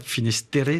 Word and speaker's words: Finisterre: Cap Finisterre, c'est comme ça qu'on prononce? – C'est ça.